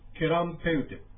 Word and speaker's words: ケランペウテク: これも実は、クエランペウテクというアイヌ語がつながりまして、ケランペウテクというふうに発音します。それでは発音していただきます。ケランペウテク。 – ケランペウテク。 – ケランペウテク。 – はい。このケランペウテクは、 M の発音が入っていますので、口を閉じるようにしてやっていただくと、正しい発音になります。